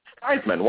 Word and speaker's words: Theismann: And Dad said, "Theismann.